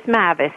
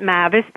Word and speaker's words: Mavis: And my name is Mavis, if you want to say it the way we call it, Mavis, but a lot of people call it Mavis, so I answer to either.